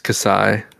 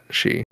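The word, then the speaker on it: Xi: That's how the Greek letter is pronounced. It's Kasai, not Shi.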